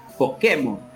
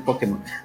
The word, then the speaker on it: Pokémon: Que me, lo que me traumaba era porque ellos te lo pronunciaban como Pokémon en vez de Pokémon.